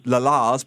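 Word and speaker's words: La's: It was a Liverpool band and they were called The Lads, but they sold it as La Lars because that's how they pronounce it in Liverpool.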